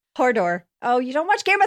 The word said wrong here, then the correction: Hodor: Oh, sorry. I said it wrong. – Hodor? – It's Hodor.